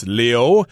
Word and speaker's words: Liu: Okay, so here we're saying his name is Simu Lu, although it's Leo in Chinese, but a lot of times we just pronounce this word as Lu in English because people who speak English sometimes just don't know how Chinese is pronounced. I think there's a famous actress in the United States called Lucy Liu. They always say Liu, even though her name is Leo.